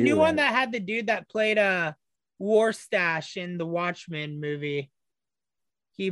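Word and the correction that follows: Rorschach: Did I say his name wrong? – Warshak. – Yes. – Warshak. – It's Warshak. – like a Warshak test.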